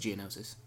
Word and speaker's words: Geonosis: Geonosha. – Geonosis. – Geonosha. – You pronounce it Geonosis.